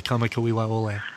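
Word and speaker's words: Kamakawiwa Ole (Kamakawiwoʻole): I got taken to task by a listener because of my mispronunciation of Israel Kamakawiwa Ole. And so it was right after that. They broke it down phonetically. And from that point on, I've always been able to, you know, I've never let myself not say Israel Kamakawiwa Ole. – Every letter is pronounced.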